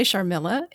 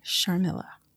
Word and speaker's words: Sharmila: You can say Sharmila. It's three syllables. And it's one of the easier Hindi names that you can pronounce. If you can pronounce supercalifragilisticexpialidocious, you can say Sharmila.